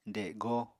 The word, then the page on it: dego: apatani